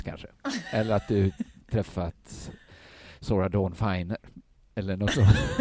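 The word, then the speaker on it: Sarah Dawn Finer: Det är fyra ån i Sora Dawn Finer. – Ja. Det är, men, all, men det är inga riktiga ån. Det är bara uttalsmässigt. – Nej, det är brittiska ån. – Det är brittiska ån, ja.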